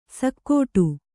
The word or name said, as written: ಶಾಕಟ